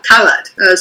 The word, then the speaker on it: Calad: Calad. Stress on the first syllable.